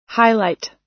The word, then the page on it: highlight: American English